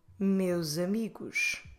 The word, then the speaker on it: meus amigos: But if I run them together and if I now pronounce the S as a Z, it sounds like meus amigos, meus amigos, which is far more elegant, it flows better, and is the preferred way for the Portuguese people to say it.